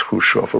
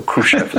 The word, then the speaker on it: Khrushchev: That's pronounced Khrushchev, or Khrushchev is fine too.